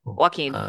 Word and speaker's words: Joaquin: Joaquin, yes.